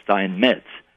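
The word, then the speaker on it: Steinmetz: my name is Stein Metz. It originally meant stonemason. The German word Stein, it's pronounced with a sh.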